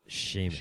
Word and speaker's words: Seamus: Did you really not know that Seamus is supposed to be pronounced Seamus? – No, dude, I'm not Irish.